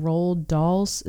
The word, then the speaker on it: Roald Dahl: So, inspired by, loosely based on Roald Dahl's, is that how you say it? – That sounds right. I think so.